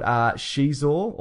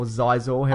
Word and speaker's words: Xizor: And a guy called Xizor, or Xizor, however you want to pronounce it. X-I-Z-O-R, I think it's pronounced.